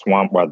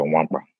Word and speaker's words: Nwankpa: It's pronounced Wampa. The the K and the N are silent, so Wampa.